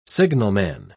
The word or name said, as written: signalman